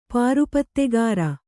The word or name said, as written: ಪಾರುಪತ್ತೆಗಾರ